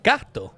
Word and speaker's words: gatto: How do you say cat in Italian? – Gatto. – Oh, with two T's. – With two T's, yeah.